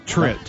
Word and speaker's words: Tritt: How does he say his name? Travis Trent. – Travis Tritt. – Travis Trent. – Tritt, T-R-I-T-T.